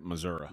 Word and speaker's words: Missouri: Is actually Missouri. How the locals say it, Missouri.